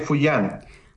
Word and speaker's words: Fujian: en específico del clan Bang Fujian. ¿No es así? ¿Se pronuncia? ¿Es correctamente? ¿Cómo se dice? – Sí. El clan Bang de Fujian.